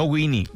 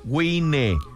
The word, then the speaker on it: Wine: Visite en Facebook Baja Wine, se escribe Baja Wine, no Winnie, Wine, Baja Wine.